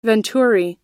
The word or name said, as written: venturi